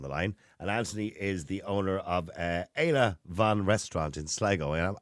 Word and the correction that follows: Eala Bhán: Yeah, you're not far off it. It's Eala Bhán restaurant and Hooked restaurant in Sligo.